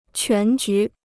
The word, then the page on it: 全局: quán jú